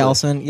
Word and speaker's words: GLSEN: Gelson, yeah.